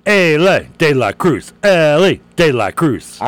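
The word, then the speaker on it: Elly De La Cruz: Like, instead of, you know, like Ali Bumbaye, it's Ellie De La Cruz.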